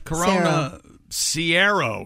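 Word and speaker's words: Corona Cero: Corona Sierra is how it's pronounced. That's according to Carter. The ER sound like air instead of ear.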